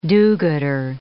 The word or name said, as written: do-gooder